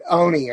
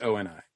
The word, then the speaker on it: ONI: The extension of the technology to the I pronounced it Oni or Ani anytime I was reading it instead of O and I.